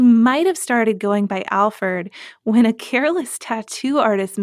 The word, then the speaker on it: Alferd: even though he's generally known as as Alferd, like with the, ERD. – Yeah, ERD.